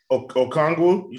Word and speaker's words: Okongwu: Is that how you say his name? Anoki Ngangwa? I know I totally butchered his name, and I'm sorry, but... – Okongwu? You talking about Okongwu? – Yeah, that's...